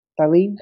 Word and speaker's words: Tallinn: One of the things that I wanted to ask you about is that I read somewhere that the city of Tallinn, did I say that right? Tallinn. Uh, Tallinn, yes, the capital.